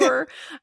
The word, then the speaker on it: lure: I think lure. I said it more like lore and then I, yeah. – And then lure.